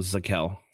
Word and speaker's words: Zekelj: Next, offensive lineman, Nick Zekelj. I believe is how you pronounce his name. – It's the J is silent, so Zekel.